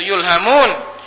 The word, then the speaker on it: yulhamun: Yulhamun, bacanya itu yulhamun.